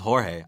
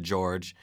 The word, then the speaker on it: Jorge: When you first see your name, I thought it was Jorge. That's how I thought it was pronounced. – Mm-hmm. – But you pronounce it George. – Yeah. – But I thought it was Jorge because it's spelled J-O-R-G-E.